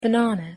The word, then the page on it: banana: English